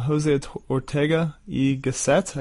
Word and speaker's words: José Ortega y Gasset: It starts with a quote from José Ortega y Gasset, I guess is how you say it. – Yes, that's right.